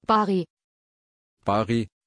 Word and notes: pari: German

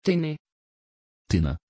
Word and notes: tine: Dutch

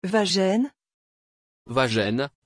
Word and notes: vajenn: French

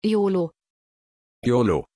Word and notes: iolo: German

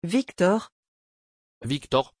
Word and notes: viktor: French

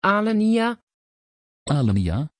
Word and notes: Alenia: Dutch

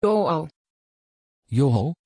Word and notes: joão: Dutch

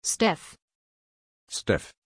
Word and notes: Stef: German

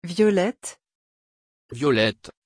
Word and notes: violett: French

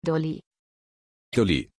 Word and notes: Doli: German

